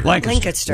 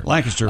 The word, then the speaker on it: Lancaster: Pat Godwin is in, how do you pronounce it? Lancaster? How did you pronounce it? – Lancaster. – Lancaster. – Lancaster. Run it all together. – You swallow the S.